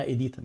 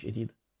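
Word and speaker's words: Edita: اسمها ايديتا مش ايديدا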